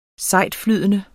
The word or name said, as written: sejtflydende